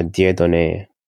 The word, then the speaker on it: Dieudonné: My first name is Dieudonné. It's a French name.